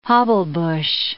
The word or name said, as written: hobblebush